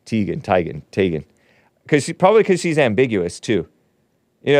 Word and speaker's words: Teigen: It's Chrissy Teigen, I think. Not Teigen, not I wanted to call it Teigen because it sounds E before I, right? Chrissy Teigen, but it's actually pronounced Chrissy Teigen, according to her mother.